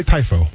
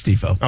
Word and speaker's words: tifo: Is that how you pronounce it? Because I don't... – That's how I pronounce it. – I'm going to say TIFO. – I think it's TIFO.